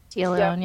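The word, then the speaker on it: Téa Leoni: she looks a lot like Taya Leone. Is that how I say her name? – Taya Leone, yeah.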